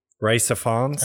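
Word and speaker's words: Rhys Ifans: Reese Ifans. So I'm not even going to like, yeah. I think that's how you say his name. – It's Reese Ifans. – Yeah, like Reese Ifans.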